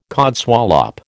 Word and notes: codswallop: American English

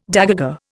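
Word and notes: dégagé: Indian

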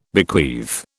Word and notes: bequeath: British English

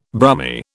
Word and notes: Brummie: British English

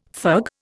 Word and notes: fug: Indian

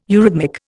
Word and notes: eurhythmic: Indian

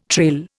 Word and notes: trail: Indian

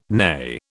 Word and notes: neigh: British English

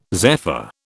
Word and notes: zephyr: British English

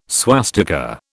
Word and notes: swastika: British English

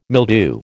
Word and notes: mildew: American English

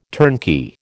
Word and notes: turnkey: American English

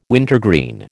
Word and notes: wintergreen: American English

